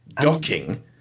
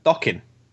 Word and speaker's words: Dokken: Docking? – Dokken. I can never pronounce it right.